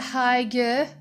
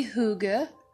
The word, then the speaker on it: hygge: First off, the art of, I used to call it hygge. But it's actually hygge in Danish, so apologies to my Danish listeners. I'm sure you all have had a good laugh at me mispronouncing Danish.